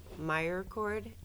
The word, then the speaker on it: Meierkord: D-A-R-C-I-E. Last name C-A-D-Y. – Darcy Katie. – Kristen, K-R-I-S-T-E-N, Meyercord, M-E-I-E-R-K-O-R-D.